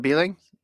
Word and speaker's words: Beling: I was thinking of Tyler Beeling. Gordon, am I pronouncing that right? – Yeah. – Okay. I'm going Beeling. – B-E-L-I-N-G.